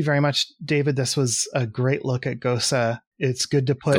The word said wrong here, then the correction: GOCE: That's how they say it. Goche. Oh, yeah, like it's an Italian word.